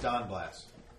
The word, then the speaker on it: Donblas: Now it's pronounced Don Blass. – Don? – Don Blass. D-O-N-B-L-A-S.